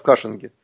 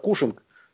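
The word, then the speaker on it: Cushing: Это все формируется, эта цена нефти как раз в Кашинге. Ну, иногда у нас произносят как Кушинг, но, скорее всего, по-американски это как Кашинг город.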